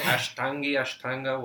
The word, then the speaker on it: Ashtanga: I did a bit of Ashtangi yoga. Sorry if I mispronounced it. Ashtanga, whatever.